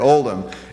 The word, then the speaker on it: Oldham: Well, in my last name, there is a letter that's called a silent letter. It's the letter H. So we say it Oldham.